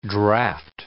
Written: draft

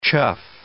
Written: chough

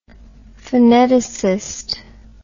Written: phoneticist

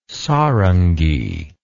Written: sarangi

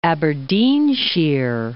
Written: Aberdeenshire

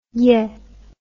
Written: yae

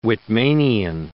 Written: Whitmanian

